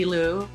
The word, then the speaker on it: Lew: And you can find me, Leslie Liu. My last name is spelled L-E-W.